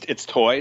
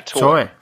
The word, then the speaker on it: Toay: It's Toay. It's pronounced just like T, what you would think, T-O-Y. But yeah, Toay.